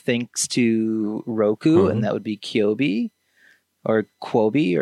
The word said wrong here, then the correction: Quibi: or whatever. – Quaba? – I think it's Quoboa? Just Quibi, Quick Bites. – Quibi.